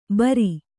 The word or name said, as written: ಭೇರಿ